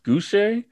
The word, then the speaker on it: Gouche: Tiffany, I think her last name is pronounced Gouche. It's G-O-U-C-H-E.